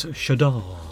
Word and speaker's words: Shada: Ooh. Or is it Shada? – No, it's Shada. – No, it's Shadar. – Shadar, okay.